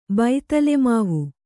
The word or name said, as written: ಬೈತಲೆಮಾವು